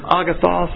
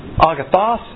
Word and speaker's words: agathos: That's the Greek word agathos. Actually, you can pronounce this agathos, A-S, or agathos. I like the A pronunciation.